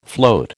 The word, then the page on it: float: American English